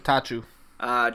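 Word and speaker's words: Attaochu: We had Jeremiah, how do you say that? Atachu? – Atachu.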